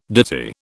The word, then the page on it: ditty: British English